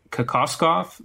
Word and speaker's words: Kokoskov: So I'm curious to see how Kokoskov, is that how you pronounce it? – Yes.